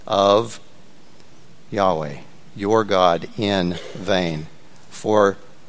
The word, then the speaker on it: Yahweh: The best pronunciation we can get close to is the word Yahweh.